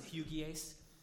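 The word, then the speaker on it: hygieis (ὑγιής): It's spelled like huggies, like diapers almost, but it's hygieis.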